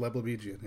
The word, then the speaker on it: Leblebigian: Once you get it, it's surprisingly easy for a name that looks that weird. It's like, oh, it's just sort of each syllable. There's no tricks. It's just Leblebigian, yeah.